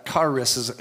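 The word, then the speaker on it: charis (χάρις): This Greek word is charis, or charis is how you say it.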